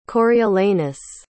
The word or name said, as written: Coriolanus